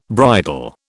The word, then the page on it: bridle: British English